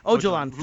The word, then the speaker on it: Öcalan: 2013, where it looked like the Kurds and, I mean, Akkwan, what the guy, the leader of the PKK? – Öcalan. – Sorry, I got his name wrong.